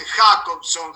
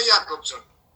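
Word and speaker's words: Jakobson: Muchos le dicen Jacobson, Jacobson. Se pronuncia Jacobson.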